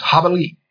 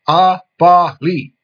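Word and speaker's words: Jabalí: My code name is Habali, spelled with a J, but pronounced with an H sound. Habali.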